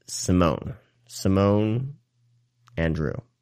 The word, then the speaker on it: Simón Andreu: And I think the actor's name is pronounced Simone Andrew.